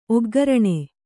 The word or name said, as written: ಒಗ್ಘರಣೆ